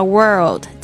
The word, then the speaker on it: world: 特别注意一下 world 这个发音， orl 这个声音呢， world 多练几次哦，那个声音拉起来比较长，不要只讲 word 就会是这个文字的意思。